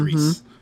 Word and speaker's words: Gris: Like, see what it is spelled Gris, but it's pronounced like mm-hmm. Greece.